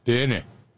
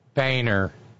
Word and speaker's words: Boehner: How do you pronounce it? And they said, Boehner. I said, that ain't going to happen in the United States. We're not that nuanced. They said, but how is it pronounced in the United States? I said, You don't want to hear this. Boehner.